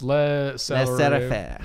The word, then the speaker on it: laissez-faire: Laissez-faire.